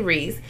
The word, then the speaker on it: Reese: Okay, anyway, so my Instagram is Naturally Reese. N-A-T-U-R-A. Am I spelling, what the fuck am I saying? Naturally, N-A-T-U-R-A-L-L-Y, Reese. Damn, I can't spell. And Reese is spelled R-E-E-S-E. Yeah. – Okay. follow me at it's Brie, B-R-I dot bih, like bitch, but bih, B-I-H.